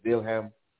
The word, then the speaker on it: Wilhelm: Let me say that correctly. It's not William. It's Wilhelm the First.